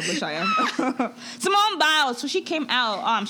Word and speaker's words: Simone: Simon? – What are you talking about? – Biles. – Simone. – I said, what did I say, Simon? – I'm like Simone.